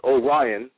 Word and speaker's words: Orion: And also, just so you know, the pronunciation of my name is Orion. It's like the constellation.